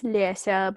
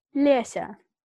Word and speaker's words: Lesya: That's because that's how my name is spelled, and my actual name is Lesya, but no one can say Lesya, which is why I go by Lisa. – Oh, say that again. Lesya. – Lesya.